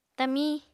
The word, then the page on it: tamiñ: apatani